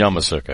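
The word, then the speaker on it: Youngmisuk: I never pronounce Om's last name right, and I should be able to pronounce people. – Yamasuk, I believe. – Yamasuk, right. You're right.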